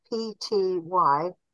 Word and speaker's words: ptyalin: Whereas your saliva has got this digestive juice, this digestive substance, ptyalin, spelt PTYALIN, ptyalin, with a silent E.